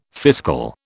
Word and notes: fiscal: British English

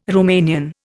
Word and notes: Romanian: Indian

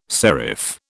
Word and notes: serif: British English

